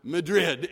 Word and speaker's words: Madrid: We had a general superintendent, a bishop, whose proposal went down solely on the mispronunciation of the town spelled M-A-D-R-I-D, which you know is not pronounced Madrid. It is Madrid.